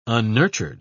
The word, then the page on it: unnurtured: British English